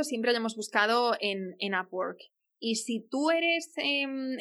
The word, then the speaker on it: Upwork: Upwork se escribe U-P W-O-R-K.